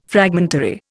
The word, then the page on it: fragmentary: Indian